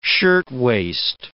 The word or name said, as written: shirtwaist